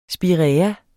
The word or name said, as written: spiræa